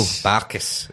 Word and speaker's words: Barkas: Barkas. That's the way Taggart would say it, which is the official pronunciation.